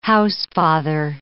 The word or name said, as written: housefather